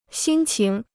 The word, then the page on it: 心情: xīn qíng